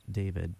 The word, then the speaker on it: D4VD: So here is a brand new song by the artist, I believe it's pronounced David, but it's spelled D4VD.